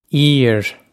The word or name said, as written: aoir